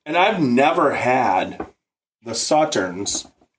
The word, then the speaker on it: Sauternes: Pretty close. You know, I'm no expert, but I'm pretty sure it's Sauternes. – Yeah. Sauternes casks. I've never had any whiskey in Sauternes casks.